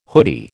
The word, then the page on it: hoodie: American English